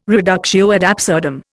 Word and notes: reductio ad absurdum: Indian

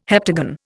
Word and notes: heptagon: Indian

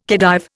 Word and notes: Khedive: Indian

